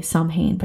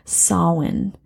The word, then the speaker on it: Samhain: So a lot of the word Samhain looks like you say Samhain, but it's actually pronounced Samhain.